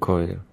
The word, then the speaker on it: Choir: ¿Cómo se pronuncia? Tenía yo la duda antes de venir. – Choir. – Choir. Mm.